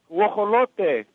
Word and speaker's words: Guajolote: Guajolote.